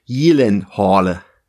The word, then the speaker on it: Gyllenhaal: Also bei uns ist er natürlich weitestgehend bekannt als Jake Gyllenhaal, aber tatsächlich ist die richtige Aussprache, das wusste er aber wahrscheinlich selber nicht, ist tatsächlich Gyllenhaal. Schwedisch.